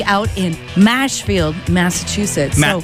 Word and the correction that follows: Marshfield: Mashfield? – Mashfield. Well, Marshfield. – Marshfield? – Marshfield, as in marshy, as in marshes.